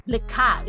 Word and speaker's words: Likha: It's L, you know, L-I-K-H. That's her name, and Likha is spelled L-I-K-H-A.